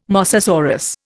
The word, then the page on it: mosasaurus: Indian